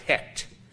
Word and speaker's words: Heqt: The frog god's name was Hekt, H-E-Q-T. So I presume that's pronounced Hekt.